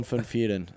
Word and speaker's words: Van Vurren: Christian van Vieren is how you pronounce it in Afrikaans.